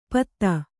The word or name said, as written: ಪೊತ್ತ